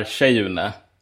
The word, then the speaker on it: Kyeyune: Vi är Kyeyune, som typ tjej, inte som tje.